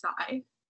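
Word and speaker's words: Cai: And one are the Montagoves, and the other gang is the Kai family. So Scarlets and White Flowers. – I think it's pronounced Sai. Just so Sai.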